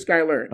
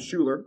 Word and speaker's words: Schuyler: It's Skyler. Okay. It's spelled right. German. It's spelled S-C-H-U-Y-L-E-R. So people fuck up his name. They call him Schuler, right? Which pisses him off. Schuler.